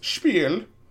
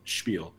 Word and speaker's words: Spiel: So Spiel. – Yes. I mean, you don't say it the way you said it. You say it like a normal person, Spiel.